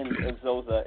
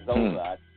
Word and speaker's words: Xhosa: And so, to his surprise, playing the Black Panther's father, Chadwick Boseman, when he spoke the language to him, he responded in Xhosa as well. Or Xhosa, is what it's pronounced.